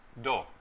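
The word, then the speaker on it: do (道): La pronunciación japonesa para Dao es do.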